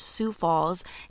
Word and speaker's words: Sioux Falls: We actually mispronounced the city that Daphne was, well, the city that Daphne committed her murder in. The name of the city is actually called Sioux Falls, named after the Native American Sioux tribe.